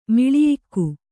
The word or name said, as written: ಮಿಳಿಯಿಕ್ಕು